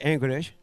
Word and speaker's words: Anchorage: sí este, el, en Alaska hay una parte que el que quiera ir a conocer, y esto es verdad, podés ir a Anchorage, que se pronuncia Anchorage, mirá cómo estoy.